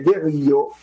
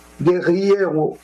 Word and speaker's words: guerrillero: au dire de l'ancien guerrillo. – Alors, comment on prononce ça? Guerrillero.